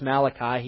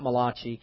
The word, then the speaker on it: Malachi: It's pronounced Malachi. He's not Italian. It's not Malachi.